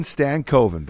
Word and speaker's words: Stankoven: Logan Stankoven, bud.